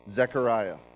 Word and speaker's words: Zechariah: Is that how you say it? Is it an E? Zechariah, yes. 3:1-4.